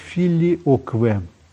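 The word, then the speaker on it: filioque: И постепенно вот символ веры с этой вставкой, а она по латыни звучит как «филиокве».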